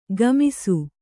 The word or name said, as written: ಕುಮ್ಮಿಸು